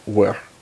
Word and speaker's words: Wer: Is it just W-E-R-E? – W-E-R. So, I think it's actually supposed to be pronounced Where.